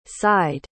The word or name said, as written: sighed